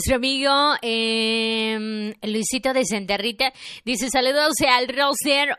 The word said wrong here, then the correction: rooster: o sea, al gallo, por los que no saben inglés. ¿Cómo se pronuncia? Rooster.